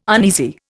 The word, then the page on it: uneasy: Indian